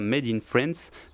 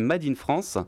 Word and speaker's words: Mad in France: Mad s'écrit M-A-D, hein. Ce n'est pas Made in France, c'est Made in France, qui sera suivi d'un morceau en espagnol, un featuring en fait, qui s'appelle Del Sur Al Norte.